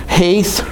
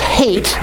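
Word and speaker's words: Heth: Now let's finally turn to Psalm 119, and we're in Haith, sometimes pronounced hate, which works pretty well for our lesson today.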